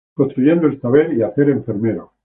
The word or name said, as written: saber